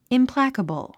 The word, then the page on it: implacable: implǽkəbl; インプラァカボォ